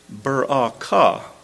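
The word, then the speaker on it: Barakah (בְּרָכָה): That word blessing that we just read, if you'd like a little bit of Hebrew lessons today, that's pronounced Barakah. Barakah.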